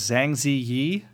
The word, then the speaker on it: Zhang Ziyi: I'm gonna say this wrong. Zhang Zi Zi. – I, I most often see it as Zhang Zi Yi. Zhang Ziyi.